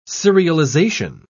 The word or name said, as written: serialization